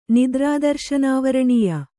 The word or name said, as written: ನಿದ್ರಾದರ್ಶನಾವರಣೀಯ